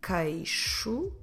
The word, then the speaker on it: queixo: So if I want to say he has pain in his chin, the word for chin is queixo, the chin.